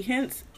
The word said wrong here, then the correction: Henson: what's her name? Taraji P. – Henson. – Okay. I said it wrong.